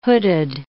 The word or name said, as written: hooded